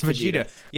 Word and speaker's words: Vegeta: It's Vegeta.